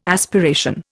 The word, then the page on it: aspiration: Indian